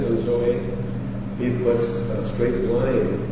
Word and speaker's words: Zoe: Zoe is how you pronounce it, and it means life. Your life is hid with Christ in God, Colossians 3:4. See how nice? When you see that transliterated, you would see it Z-O-E, and many would pronounce it Zo or Zozo, but it's wrong.